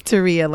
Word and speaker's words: Terea: No, it's Terea, like Maria.